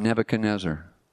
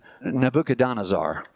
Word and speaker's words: Nebuchadnezzar: Nebuchadnezzar. I can remember as a young kid when I first saw that name in grade school, I pronounced it Nebuchadnezzar. It's Nebuchadnezzar.